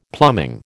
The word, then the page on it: plumbing: American English